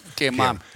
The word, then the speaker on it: Kema: They're going to, I think I said this wrong last time, the Kema experience? – Kema. Yeah, Kema.